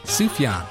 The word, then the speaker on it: Sufjan: Sufjan.